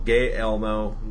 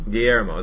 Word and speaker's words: Guillermo: Guillermo. It's like Guillermo. – You can't say it. – You need a little ethnicity in you. – You got to roll your R.